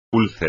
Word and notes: úlcera: espagnol

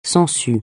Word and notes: sangsue: francais

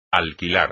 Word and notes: alquilar: espagnol

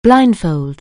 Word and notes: blindfold: anglais

